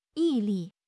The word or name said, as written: 毅力